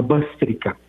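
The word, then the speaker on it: Bhastrika: esa es una técnica que se llama bástrica, la pueden encontrar en Google.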